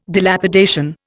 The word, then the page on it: Dilapidation: Indian